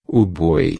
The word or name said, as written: boi